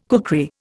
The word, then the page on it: kukri: Indian